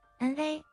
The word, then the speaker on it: 啊: 第二种情况，前面的字以 u 结尾，包括 a o i a o 啊读成哇 ,wa, 比如你在哪儿住啊，多好啊，快走啊。第三种情况，前面的字以 n 结尾，啊读成哪 ,na, 比如好难啊，真好看啊，该吃饭啊。第四种情况，前面的字以 ng 结尾，啊读成 na, 比如真香啊，好冷啊，真行啊。